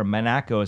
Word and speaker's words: Monaco: You know, you've been in America, you've been in Monaco, which is where you're based, or Monaco is how they say it.